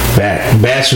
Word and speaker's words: Bach: I don't know how you pronounce it. Batch, B-A-C-H, whatever that dude. – He's Batch.